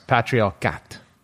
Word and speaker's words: patriarcat: Okay, I got it. Sorry, it's patriarcat.